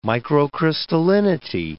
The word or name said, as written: microcrystallinity